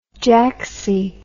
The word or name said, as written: jacksie